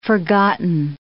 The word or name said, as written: forgotten